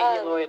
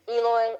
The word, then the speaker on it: Eloi: Yeah, I think they say Eloi in the movie. – Eloi, L-O-I is how I always pronounce it. I can't get it out of my head. It's like Hermione. – If you were French, you could say Eloi.